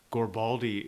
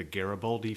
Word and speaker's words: Garibaldi: Gorbaldi is a mispronouncing of the Garibaldi fish, which are like little fish off the coast over here.